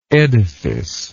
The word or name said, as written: edificial